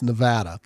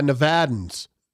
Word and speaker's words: Nevada: It's Nevada. – Oh, really? – I learned that a while ago. I got educated very aggressively by Nevadans. That it is not Nevada.